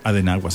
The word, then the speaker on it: Adenauer: Es que se escribe Adenauer, pero creo que se pronuncia Adenauer. – Creo que se pronuncia Adenauer. – Adenauer, sí, correcto.